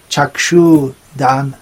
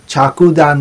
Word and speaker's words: चक्षुदान: तो गुरु बंगाली शब्द संस्कृत शब्द चक्षु और कुछ हिंदी बोलने वाले चक्षु दान ऐसे कहते हैं, बंगाली में चक्षु चक्षु वही उच्चारण है, चक्षु चक्षुदान बोलने से बड़ी छूटी नहीं है, चाकूदान नहीं बोलना चाहिए, रसाय को चाकू देना चाहिए.